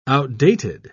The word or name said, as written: outdated